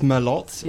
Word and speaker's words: Merlot: Yes, it's Merlot.